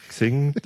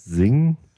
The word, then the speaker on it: Xing: Also wir sagen selber Xing. Wenn wir Englisch reden, sagen wir meistens Sing. Es gibt aber definitiv keine Ansage, wie man es aussprechen soll.